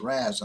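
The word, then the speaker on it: Ras: I always mispronounce his name. It's Raz, and I keep saying Raz.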